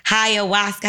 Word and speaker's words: ayahuasca: When you did ayahuasca, how you say it? – Ayahuasca.